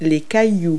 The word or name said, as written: rocks